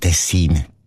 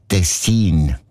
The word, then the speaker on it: Tessin: Also klar, Tessin. Aber der SRF-1-Hörerin Regula Stern-Grieser von Locarno ist aufgefallen, dass gewisse Leute statt Tessin Tessin sagen, also Betonung auf mehr, mit einem kurzen I.